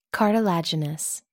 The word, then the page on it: cartilaginous: American English